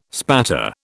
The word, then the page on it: spatter: British English